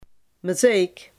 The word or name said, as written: Maaseik